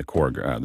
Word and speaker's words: Korg: On Wednesday, we've got a lab, and I hopefully will not butcher this, but it's Core. Is it Core? – Korg. Korg.